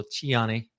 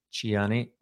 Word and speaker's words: Tiani: So the top general, Abdurrahman Tiani, his name is also Tiani. That's how they pronounce it. Tiani or Tiani. Either or.